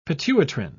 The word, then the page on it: pituitrin: British English